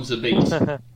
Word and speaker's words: Zabit: I just call him Zabit.